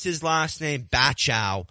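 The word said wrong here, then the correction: Bauchou: PGA Tour Media Communications is telling me it's Bushu, Zach Bushu.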